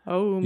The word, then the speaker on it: Oumuamua: Oumuamua.